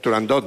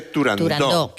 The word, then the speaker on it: Turandot: Turandot no, se dice Turandot, que es... – Como lo pronunciaba Puccini. – Como lo pronunciaba Puccini y como lo pronunciaba Toscanini.